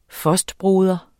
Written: fostbroder

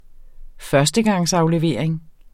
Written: førstegangsaflevering

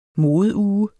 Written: modeuge

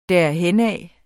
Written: derhenad

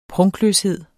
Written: prunkløshed

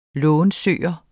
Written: lånsøger

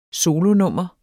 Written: solonummer

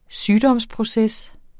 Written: sygdomsproces

